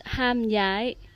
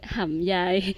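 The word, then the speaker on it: ham yai (ห้ามย้าย): The sign should be pronounced as ham yai, meaning do not move. So it's like they have a wet floor there and they have the sign, ham yai. – Yes. Ham yai, yes. – Unfortunately, I didn't pronounce the tones clearly enough, so he heard something like ham yai.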